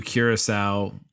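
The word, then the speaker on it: curaçao: So I'm going to go with translation, sugary, sugary, sugary, sugary, sugary, blue curacao, curuco, curco, curaco. Whatever. – Curious. You know you said it right the first time.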